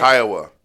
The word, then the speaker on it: Kiowa: Let's watch that run once again with Kiowa. Am I saying that right? – Kiowa. Yeah. Kiowa. Iowa with the K.